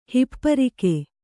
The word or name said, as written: ಹೆಪ್ಪರಿಗೆ